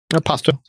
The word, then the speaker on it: Pasto: Am I pronouncing that right? Pasto or is it Pasto? – Pasto. That's pasto. – It's okay. Pasto. It tastes very nice. – Yeah, I was thinking it sounds like pasta.